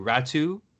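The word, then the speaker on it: Räty: Speaking of draft eligible prospects, I mentioned that there's one coming up in 2021, and that would be Aatu Raty, who, before we started recording, you told me how to pronounce that name correctly. R-A-T-Y is the last name, pronounced R-A-T-U.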